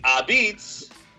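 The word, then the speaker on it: apizza: Which is spelled a pizza. – That's true. – Okay? – That's true. – It's spelled a pizza. – That's hard to say. It's a beats.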